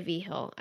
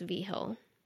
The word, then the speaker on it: Vigil: It's spelled V-I-G-I-L, but it's pronounced V. Hill.